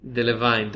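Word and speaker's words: Delevingne: וקרה דבלין? דבליניה? איך מבטאים את ה-G&E הזה? – דלוויין, כן.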